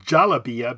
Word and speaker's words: galabiya: And then Jalaba is D-J, this is more for North Africa, D-J-E-L-L-A-B-A. – Wow, okay. – And then it can be also spelled Jalabia because the G is sometimes pronounced, the G is pronounced J in most Arabic, G-A-L-A-B-I-Y-A.